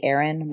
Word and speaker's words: Erynne: You can find me on Instagram and Pinterest under essentially Erynne.